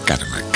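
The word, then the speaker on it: Carme: Pueden poner Karma Canela, es Carmen, catalán es Carmen, pero sin la N final, pero se pronuncia Karma.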